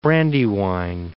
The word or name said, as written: Brandywine